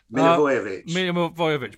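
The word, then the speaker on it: Milivojević: Milivojevic, which I still can't pronounce now, as you correctly pointed out.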